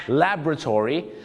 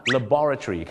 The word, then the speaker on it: laboratory: อเมริกาจะออกเสียงว่า laboratory the laboratory แต่ชาวอังกฤษจะออกเสียงว่า laboratory ครับ laboratory laboratory อเมริกา laboratory ก็คือชาวอังกฤษ